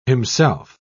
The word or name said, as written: himself